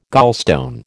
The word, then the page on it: gallstone: American English